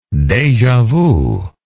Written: deja vu

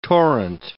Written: torrent

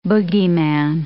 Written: bogyman

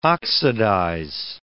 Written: oxidize

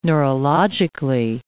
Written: neurologically

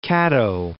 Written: Caddo Lake

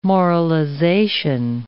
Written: moralization

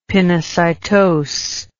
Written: pinocytose

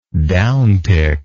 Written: downtick

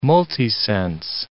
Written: multisense

